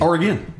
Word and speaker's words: Oregon: A lot of people say Oregon. – You're saying it correctly. – It's Oregon.